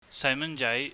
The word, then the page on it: saimanjai: cantonese